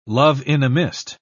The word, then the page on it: love-in-a-mist: British English